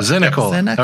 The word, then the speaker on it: Zancola: Well, just so our audience knows, this is Matt Zancola. Am I pronouncing the name correctly? – There's another syllable in there. It sneaks in there. It's Zancola. – Yep. Zancola.